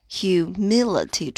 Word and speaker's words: humility: humility，注意重读音节在第二个音节上。 humility，名词，谦逊、谦恭。